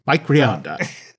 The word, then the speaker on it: Rionda: I have to say, of the bunch, I think I enjoyed Mike Rionda. – Rionda. – He listens to the show, so you have to say his name correctly. – Mike Rionda.